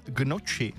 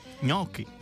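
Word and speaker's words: gnocchi: A taky Marťa, ahojda, tak mě tahá za uši, když někdo řekne, že by si dal gnoči. Správně je ňoky.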